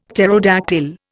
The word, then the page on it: pterodactyl: Indian